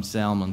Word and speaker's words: Salmon: It's got an L in it. I'm going to call him Salmon because he's a human being, so we're going to pronounce his L.